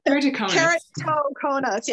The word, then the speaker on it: Keratoconus: And you were recently featured on the Alliance of Vision and Vision Research's World, I'm going to mispronounce this word, my apologies, Keratoconus Day Congressional Briefing. – Keratoconus, yes.